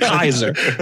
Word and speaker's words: Kaiser: I called myself the Caramel Kaiser because I like to pronounce it right. Caesar is incorrect. You actually pronounce it Kaiser.